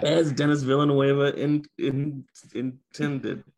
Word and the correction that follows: Denis Villeneuve: This is how you say it. Denis Villeneuve.